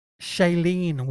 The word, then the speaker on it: Shailene: And it's Shailee Woodley. Right. She was in the Divergent films. – See how Siri wants to say it. – Shailene Woodley. – Shailene.